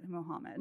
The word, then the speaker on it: MMMD: It's written out as MMMD, but they pronounce it Mohammed.